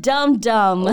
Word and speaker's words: dumb: 生活确实需要一些傻傻的小事儿去调节一下啊。那最后一个表达教给大家，日常当中啊用的比较多的，也很可爱，dumb dumb，d u m b。但是大家注意最后一个字母 b 是不发音的，所以呢也可以直接写成 d u m。那这个表达可以用来形容你那不懂女人心的男朋友，哎，带点小俏皮的意思。你这个大笨笨，you big dumb dumb，